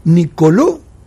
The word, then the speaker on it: Nicolò: Nicolò. Tresoldi. Nicolò con acento en la O. – Nicolò Tresoldi.